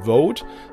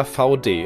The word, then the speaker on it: Vaude: Heute spreche ich mit einem Unternehmen, wo sich vielleicht manche fragen, wie wird es eigentlich genau ausgesprochen? Vote oder VD? Es ist letzteres, bestehend aus dem Namen der Gründerfamilie von Dewitz und ich spreche mit Miriam Schilling.